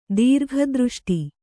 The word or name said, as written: ದೀರ್ಘದೃಷ್ಟಿ